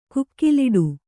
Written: ಕುಕಿಲಿಡು

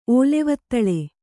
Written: ಓಲೆವತ್ತಳೆ

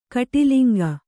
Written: ಕುಟಿಲಾಂಗ

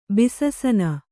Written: ಬಿಸಸನ

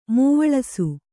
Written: ಮೂವಳಿಸು

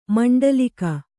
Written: ಮಂಡಲೀಕ

